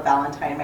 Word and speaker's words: Valentin: As Phil said, I'm Michaela Valentine. My last name is spelled V-A-L-E-N-T-I-N, and I'm with OPPD down on 16th Street in Omaha.